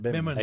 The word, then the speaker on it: bimini: Is that how you say it? Yeah, bimini.